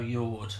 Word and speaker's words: Jord: Is it Jord? Perhaps a soft J? – I would go Jord. – Okay, Jord. And the band are called Merle, spelled yes. M-O-L, and the O's got sort of a strike through.